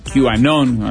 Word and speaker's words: QAnon: Una columna para intentar explicarnos qué es este movimiento, quizás lo escucharon, QAnon, así se pronuncia, se escribe con la Q y Anon.